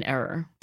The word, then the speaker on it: err: Er, like E-R-R. – Er, like the old city in Iraq, Babylonia, or wherever? – No, er, as in to commit an error. – Err. Oh, we can err. To err is human? – Did I mispronounce that? – It's a South Jersey alternate pronunciation.